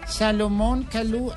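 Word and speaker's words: Salomon Kalou: Uy, qué pronunciación, doña Salomón Calú Armand Magloiri. Déjelo en Salomón Calú.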